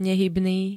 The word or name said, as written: nehybný